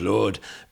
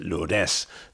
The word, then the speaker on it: Lourdes: Yeah, I know the French pronunciation is Lourdes, but here they pronounce it Lourdes, so that's what I will do.